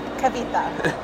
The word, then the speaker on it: Kavitha: Kavitha.